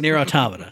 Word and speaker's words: Nier Automata: No, it's Nier Automata. Stop it, everyone. Everyone else in the world, I know you know. Everyone else in the world who doesn't know, that's how you pronounce it.